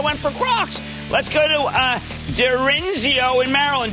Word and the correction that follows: Darienzo: Derenzio. – Jim, it's Darienzo. Greetings from Darienzo, sorry about that mispronunciation.